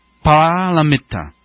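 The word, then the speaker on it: pāramitā: 波罗蜜多是印度梵文，正式的读音呢应该是 Paramita，翻译成汉文呢就是波罗 Para，是彼岸 ,Itta，即是到达。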